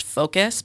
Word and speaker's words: Focos: They're not necessarily like apps for filters, but one is called, I believe it's pronounced Focus, but it's Focos.